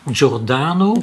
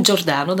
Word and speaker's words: Giordano: Giordano. Giordano, dat is Giordano, oké.